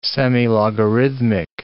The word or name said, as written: semilogarithmic